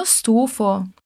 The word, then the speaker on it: stufo: In questo caso, per dire che sei stanco di qualcosa, puoi dire anche sono stufo. Attenzione alla pronuncia di questa S. Stufo. Un'altra volta. Sono stufo. Sono stufa.